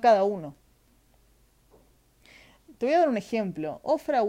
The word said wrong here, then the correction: Oprah: Winfrey, oh, Oprah, lo dije mal chicos, Oprah.